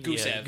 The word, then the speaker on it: Gusev: We got Gustav out there who could be another breakout. Is that how you say his name? – Gusev. – Gusev. There we go. – My apologies. You just added a T.